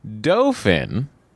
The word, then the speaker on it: Dauphin: We're talking about the Dauphin. – That's how you say it. – That is the pronunciation of the Dauphin, as I had always called it my entire life.